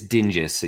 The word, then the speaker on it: Dingus: It is Dingus. Yeah, it's Dingus. Like a hard G in there.